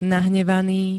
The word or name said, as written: nahnevaný